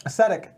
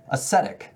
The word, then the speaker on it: Ascetic: I don't even know how to pronounce this word. Ascetic? – Ascetic. – Ascetic. – There's no other T.